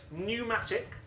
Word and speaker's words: pneumatic: Pneumatic, knowledge, and gnome. Oh, I think that was just the Big Nerd alert. – Silent letters. – They have silent letters at the beginning, and that, I'm afraid, is the end of round one with a triumphant 3-0 score to Big Nerd.